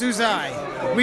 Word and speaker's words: Uzay: It's actually pronounced Uzay. We talked to some Turkish people, but I don't want to correct you because you found all of them, but that's what a Turkish person said.